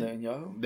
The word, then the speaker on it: Netanyahu: Uh, Net, Net, Netanyahu.